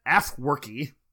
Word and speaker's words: Afwerki: In 1993, President Afwerki, that's how you pronounce it, has overseen an authoritarian, brutal regime that rests on massive human rights violations.